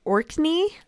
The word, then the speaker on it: Orkney: Victor makes his way to a barely inhabited village on the Orkney Islands. I think that's how you say it. It's O-R-K-N-E-Y? – Maybe. Yeah.